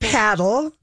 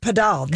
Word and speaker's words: padel: Americans call it paddle. But when you go to Mexico or anywhere else in the world, it's pronounced padel.